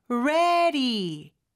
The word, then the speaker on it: ready: ready D가 모음 소리 사이에 껴서 리을처럼 부드럽게 발음이 되는데요. 그 뒤에 오는 to도 모음 사이에 껴요. 그래서 ready to, ready to 이렇게 리을 발음처럼 두 개를 연이어서 해주시면 됩니다. 그 다음에 잠을 자다의 sleep 길게 해주시고요.